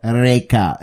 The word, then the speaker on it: Rekha: I'm like, Rekha. He's like, Rekha.